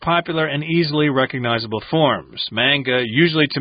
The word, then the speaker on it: manga: Indeed, and we've got the word manga here, M-A-N-G-A, which is from Japanese, but in English, oftentimes, you know, A-N words are an, like man, can, etc. So we say manga, even though in Japanese it's manga.